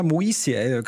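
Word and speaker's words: Moissi: Ja, Alexander Moisi. Kann man Moisi auch aussprechen.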